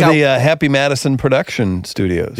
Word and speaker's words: Happy Madison: Happy Madison Productions, you know, Adam Sandler's studio.